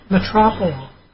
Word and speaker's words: metoprolol: She says she's taking 50 milligrams of metoprolol.